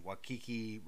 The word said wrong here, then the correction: Taika Waititi: Wati- Taika Waititi.